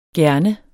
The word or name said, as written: gerne